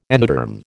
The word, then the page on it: endoderm: American English